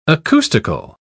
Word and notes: acoustical: British English

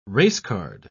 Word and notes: racecard: British English

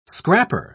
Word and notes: scrapper: British English

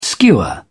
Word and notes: skua: British English

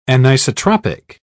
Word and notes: anisotropic: British English

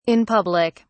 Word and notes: in public: British English

